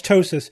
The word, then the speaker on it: ptosis: Yeah, I mean, definitely P-T-O-S-I-S is ptosis. – Yeah, no, it's funny, but... – That's ptosis.